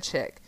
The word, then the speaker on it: chic: Kids, look up chick jeans, and it's spelled like chic, you know, but everybody said chick.